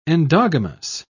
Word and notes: endogamous: British English

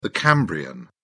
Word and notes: permian: British English

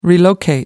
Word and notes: relocate: American English

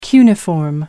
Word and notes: cuneiform: American English

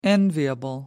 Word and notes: enviable: American English

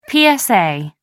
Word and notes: psa: American English

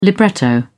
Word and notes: libretto: British English

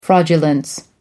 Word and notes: fraudulence: American English

